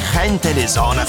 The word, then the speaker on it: Gente de Zona: Gente de zona. In realtà la pronuncia è spagnola perché sono cubani. Gente de zona,